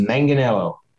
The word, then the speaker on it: Manganiello: Manganiello. – Joe Manganiello.